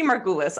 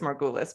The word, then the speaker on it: Margulis: It's my maiden name, but my parents come from Argentina, so they've always said it with an accent. So I just never get it right. – So tell me how do you pronounce it? – Yeah, but I'm going to say Margulis. I just like, that's what I just do is Margulis, but it sounds very different coming from my parents.